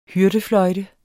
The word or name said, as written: hyrdefløjte